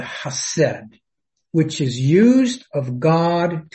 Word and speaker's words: chesed: Now it's pronounced chesed. Its first sound is a guttural sound, kind of like you're clearing your throat.